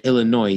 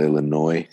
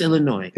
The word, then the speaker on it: Illinois: And speaking of confusion, people who say Illinois instead of Illinois. – It's Illinois. It was, we all know it's Illinois.